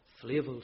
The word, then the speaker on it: Flavel: I'm reminded of the great Puritan preacher John Flavel, or Flavel, some might pronounce his surname.